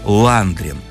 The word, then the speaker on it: Ландрин: Так вот, все дело в том, что у Феди того фамилия была Ландрин. Только с ударением на первый слог.